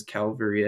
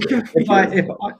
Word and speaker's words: cavalry: and it says every time someone says Calvary, Ed Bears kills a kitten. And it's just, that was. – Cavalry. – You keep saying cavalry. If I messed that up, I say it correctly. – Say it again. – Cavalry. – Yes, you say it right.